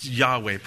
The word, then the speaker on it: Yahweh: It's the four consonants, YHWH, pronounced Yahweh, probably the most accurate.